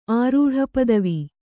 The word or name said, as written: ಆರೂಢಪದವಿ